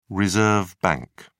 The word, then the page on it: reserve bank: British English